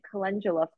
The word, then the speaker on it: calendula: And so some of the favorite plants and herbs that you mentioned was turmeric, cayenne pepper, and calendula flowers. Did I say that right? – Mm-hmm. Yes, you did. Calendula.